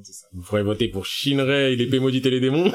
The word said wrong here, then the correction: Shinra: c'est Shinra, c'est juste Shinra.